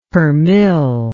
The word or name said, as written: permill